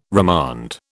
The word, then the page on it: remand: British English